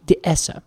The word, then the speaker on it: dehesa: If we are in Spain, it's called a dehesa. – Dehesa. – Dehesa. Hmm. If we are in, or rather, I'm sorry, the Spanish pronunciation would be dehesa.